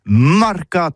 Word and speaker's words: marcat: Eh bien, dis ça à tous, mais le mercat de l'Uni ou le mercat des rebelles, mais en gascogne toulousano, c'est prononcé sur les marcats, avec un A, marcat. En gascogne toulousaine, bah, du côté, par exemple, de l'île des Baches, c'est l'île Jourdain, pour parler du marché, vous prononcez les marcats.